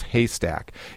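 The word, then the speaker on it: HASTAC: She's the co-founder of Humanities, Arts, Science, and Technology Advanced Collaboration, and that is an abbreviated, that's pronounced Haystack.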